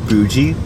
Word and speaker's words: bougie: Did the term bougie... – Bougie? Why do you pronounce it bougie? – The term bougie... – A hard G. – The term boogie... – That's how you say candle in French.